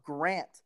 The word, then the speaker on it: Grant: They're like, when I say my name, they're like, oh, Brant? And I'm like, no, Grant, not Brant, you sick fuck.